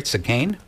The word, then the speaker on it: Cinquain: Did I pronounce it right, Cinquain? All right, awesome.